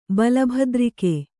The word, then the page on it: ಬಲಭದ್ರಿಕೆ: Kannada